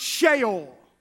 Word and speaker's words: Sheol: But I want you to look at that word grave there, because it is the word, and we pronounce it down here in the South, Sheol. Our Western culture says Sheol. It is actually pronounced Sheol.